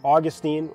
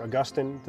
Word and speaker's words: Augustine: It was basically the way that Augustine, or Augustine, depending on how you pronounce his name, Thomas Aquinas, it's how the Roman Catholic Church does it, and then also Luther.